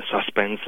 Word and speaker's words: suspense: Eh bien, personnellement, tu t'en es rendu compte, là, je prononce pas le mot suspense à l'anglaise. Je dis suspense parce que c'est un emprunt à l'anglais qui vient lui-même du mot français « suspens ».